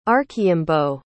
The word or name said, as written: archaimbaud